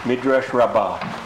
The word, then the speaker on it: Midrash Rabbah: Enjoy your pronouncing it correctly in the Hebrew way, Midrash Rabbah. – Okay. – Most people would say Midrash Rabbah.